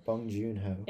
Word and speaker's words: Bong Joon-ho: Joon-ho Bong Joon-ho.